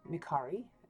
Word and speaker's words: Micari: My name is Mary Elizabeth Micari, M-I-C-A-R-I, as I say. To spell it correctly, it is not Maccari, it is an Italian last name, Micari.